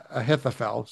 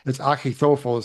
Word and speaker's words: Ahithophel: Then Absalom sent for, I know it spells like Ahithophel. Some people say it that way, but if you look at the linguistic, it's Achitophel is how you pronounce that name.